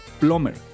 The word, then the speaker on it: plumber: Se escribe plumber, pero se pronuncia plumber.